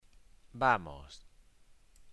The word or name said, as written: vamos